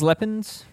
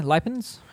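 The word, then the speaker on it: Liepiņš: Emils Lepins, is that how I, Lepins? How would you say it? The Latvian rider with Trek Segafredo? – That sounds right. Wrong, but none of us are going to get any closer.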